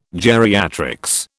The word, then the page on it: geriatrics: British English